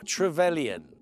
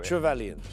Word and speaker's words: Trevelyan: It also opens up Alec Trevelyan.